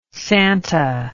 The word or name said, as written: Santa